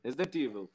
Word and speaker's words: Resident Evil: Eu também, eu não sei nada de Resident Evil. Resident Evil! Resident Evil, falei certo.